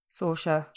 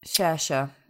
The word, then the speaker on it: Saoirse: And talking about the cast, you've got Saoirse Ronan. Have I said that right? – Saoirse, like inertia. I heard her say that once on. – Okay. Saoirse, apologies.